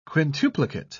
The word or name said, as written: quintuplicate